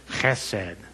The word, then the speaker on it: hesed: If you want to sound really legit, you have to take that first part of it and you make it really guttural. You go, hesed is how you say it.